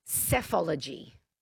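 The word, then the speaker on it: psephology: It's, yeah, and it's pronounced cephology.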